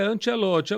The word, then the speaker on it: Ancelotti: É, como é que tu pronuncia? Ancelotti, é? – É, Ancelotti. Agora, Ancelotti.